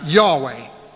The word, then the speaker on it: Yahweh: It has, we translate it with four letters, and we see it spelt in English as YHWH. And people tend to pronounce that Yahweh.